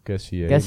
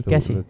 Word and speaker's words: Kessié: Barcelona versterkt zich met AC Milan middenvelder Kessié. – Of Kessié. – Kessié, ja. Oh, kan ook nog. Het is net hoe je het uitspreekt.